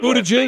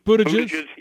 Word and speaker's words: Buttigieg: Buttigieg. – Last name. – Buttigieg, yeah.